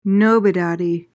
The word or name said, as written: nobodaddy